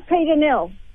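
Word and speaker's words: Pieter Nell: This is Peter Nell.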